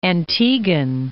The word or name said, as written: Antiguan